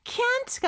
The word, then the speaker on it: can't: can't 같은 경우는요, 마치 can처럼 들릴 수 있지만 약간 콧소리가 들어갑니다. can, 이렇게. 그리고 마지막 t는 잘안 들려요. 근데 끊어주는 느낌이 있어요.